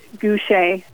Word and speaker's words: Gaucher: Sure, it's Gaucher.